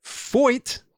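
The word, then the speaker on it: Feucht: I looked up how to pronounce his name. Don't worry, because it is spelled F-E-U-C-H-T. F-E-U-C-H-T. And I decided I should look it up. – Switch. – Yeah. Because I didn't just want to pronounce the letters as they might look.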